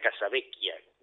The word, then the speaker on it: Casavecchia: No, Casavecchia lo dije bien. Sí, porque es italiano, ahí me hago el pini. Virginia Casavecchia. Doble C, H, esquia.